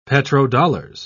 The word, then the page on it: petrodollars: British English